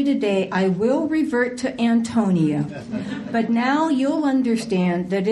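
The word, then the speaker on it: Ántonia: even though there is clearly an accent on the first syllable of the word Antonia in every edition of the book ever published.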